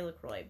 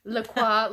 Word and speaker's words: LaCroix: I will not correct you if you say LaCroix, but if you come for me and you say, why are you saying LaCroix? That's wrong. LaCroix. I'm like, that's how you say it. It's a French word.